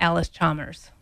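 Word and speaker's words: Allis-Chalmers: John Howery representing John Deere and Bruce Gold representing Alice Chalmers.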